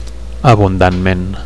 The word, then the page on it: abundantment: Catalan